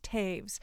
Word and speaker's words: Toews: It looks like her last name is pronounced Toews, T-O-E-W-S, but it's pronounced Taves.